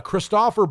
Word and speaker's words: Christoffer: And it's directed by Christopher, and I did actually pronounce the name correct, Christopher, C-H-R-I-S-T-O-F-F-E-R, which looks like Christ offer, Bo. And Christopher Bo is a Danish director, and he's relatively young.